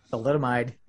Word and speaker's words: thalidomide: thalidomide, yep, during pregnancy, marketed as relief for morning sickness, began to give birth to children suffering some pretty fucked up deformities.